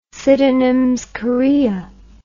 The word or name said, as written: sydenhams chorea